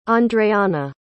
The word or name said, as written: andreanna